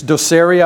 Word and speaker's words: docere: The Latin equivalent of rabbi comes from the word, it's docere, I believe the way it's pronounced, D-O-C-E-R-E, which means to teach.